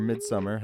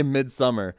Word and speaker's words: Midsommar: If you watch Midsommar, bro, or Summer, it's spelled Somar, it's Midsommar. – No, go ahead. No, you be pretentious. It's fine. You can go to No, they literally say Midsommar.